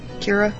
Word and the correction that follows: Kiara: Oh, Kiara. – Kiara.